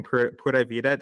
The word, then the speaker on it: Pura Vida: I'll hold up a sign saying Pura Vida.